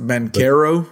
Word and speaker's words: Banchero: I think his name's actually pronounced Banchero.